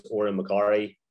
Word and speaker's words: Odhran McCorry: Basically, my name is Odhran McCorry. It's spelled kind of funny. There's a few silent letters thrown in.